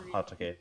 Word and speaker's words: Hatake: She's Leaf Genin, Hitaki clan, or Hatake. I guess Hatake, the proper pronunciation, I guess.